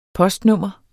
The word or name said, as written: postnummer